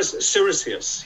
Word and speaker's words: Siricius: And presumably Bishop Siricius, is it Siricius, or did I pronounce that correct? – Siricius, yeah. S-I-R-I-C-I-U-S, Siricius.